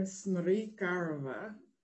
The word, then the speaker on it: Smirkarova: My name is Taylor Smirkarova, which is a Bulgarian name, many syllables.